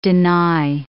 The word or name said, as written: deny